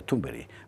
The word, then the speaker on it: Thunberg: No sé, que Carlos Esteban nos enseñó que se pronuncia Thunberg.